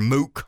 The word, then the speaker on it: Mook: Now, Mook pushed back, or Mook, however you want, I've heard it pronounced both ways, Robbie Mook, M-O-O-K.